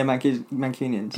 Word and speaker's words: Mancunians: Mancunians? – Yeah, Mancunians.